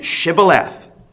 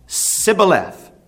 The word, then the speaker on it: Shibboleth: Instead of saying Shibboleth, the men of Ephraim would say Sibboleth.